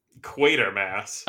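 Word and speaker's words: Quatermass: Well, it's fucking spelled quarter. – Quartermass. That's not how you spell quarter. – So how do you pronounce it? – Quartermass. – All right, because there's no R or second R in there.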